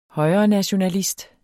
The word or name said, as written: højrenationalist